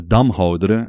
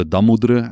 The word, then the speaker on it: Damhoudere: It's maybe first to say that the dark page of the book is that the Damhoudre, or the Damoudre, as you could pronounce it in the local dialect,